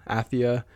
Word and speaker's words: Athia: The next game we got was Project Athia, I believe is how it's pronounced. Athia.